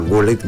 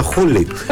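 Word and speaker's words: Gullit: Λέει το G στα Ολλανδικά προφέρεται Χ. Φίλοι Ολλανδοί δεν καταλάβαιναν όταν έλεγα γκούλιτ, με διόρθωναν και έλεγαν χούλιτ.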